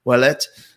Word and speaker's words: Ouellette: See, I know how to pronounce that because I'm from French Canada. Ouellette, also the name of Pierre Ouellette, who was the pirate guy.